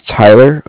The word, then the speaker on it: Tyler: My name is Tyler. It's spelled T-Y-L-E-R. And I'm actually calling with Cardiff. It's spelled C-A-R-D-I-F-F, as in Frank Frank.